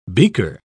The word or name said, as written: beaker